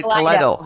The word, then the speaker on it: Kaleido: Kaleido. Kaleido. Thank you. No, it's not EO. Kaleido, and you have got a new track called Die Tryin'.